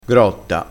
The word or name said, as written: grotta